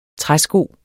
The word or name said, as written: træsko